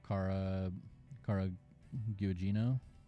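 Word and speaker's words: Carla Gugino: Oh, and then Cara Gugino. Is that right. – How you pronounce her name? – Yeah.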